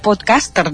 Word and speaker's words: podcaster: Però bé, aquest podcaster del, de l'anglès, en català diuen que s'hauria de pronunciar així podcaster, amb accent a, a la E, eh?